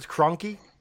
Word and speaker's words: Kroenke: So is that how you pronounce it, Kroenke? – Yeah, I took a run at it and failed miserably, but.